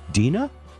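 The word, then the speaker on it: Deana: I'm getting told that she pronounces it. Is it Dina? Ah, okay. I'll get that right.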